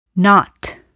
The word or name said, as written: knot